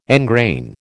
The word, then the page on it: engrain: American English